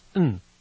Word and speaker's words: and: And is pronounced n, Roseanne and Avi.